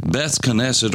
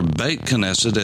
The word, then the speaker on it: Beit Knesset: And when you put the word Beit with it, which is the word for house, Beth, as we would call it, B-E-T-H, Beth Knesset or Beit Knesset, as it's pronounced in modern Hebrew, means the house of assembly.